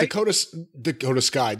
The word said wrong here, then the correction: Dakota Kai: Dakota Kai. – Dakota Kai. Do you have to say it like the way Dakota Kai? – You have to say Dakota Kai.